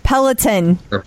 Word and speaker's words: Peloton: And I was told to make sure, I was told by my heart rate tribe to make sure we work on your pronunciation. It's Peloton. – Peloton.